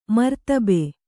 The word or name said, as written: ಮರ್ತಬೆ